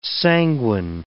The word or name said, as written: sanguine